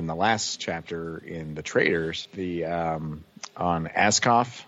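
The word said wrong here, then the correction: Askone: am I saying that right? – Ascone. – So back in Ascone.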